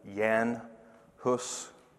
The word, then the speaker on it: Jan Hus: If you notice on your outline, there's John Wycliffe, and then we pronounce his name John Huss. It's actually Jan Hus, J-A-N, but it's John.